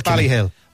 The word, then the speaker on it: Ballyhale: It's Ballyhale.